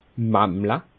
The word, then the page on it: mammla: Bas Rhin; Schiltigheim